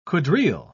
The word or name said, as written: quadrille